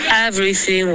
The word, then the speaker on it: everything: 等等，Everything was figured out。 – OK，其实这个连的还不错，然后发音不错，注意一下 everything 这里 th 的音，舌尖贴着上齿，OK，刚刚发成 everything 的感觉。